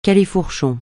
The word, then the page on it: califourchon: francais